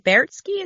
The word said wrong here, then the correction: Baertschi: is that how you say his name? Bertsky. – Ter- Baertschi. I'm terrible with names. Baertschi.